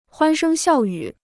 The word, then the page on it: 欢声笑语: huān shēng xiào yǔ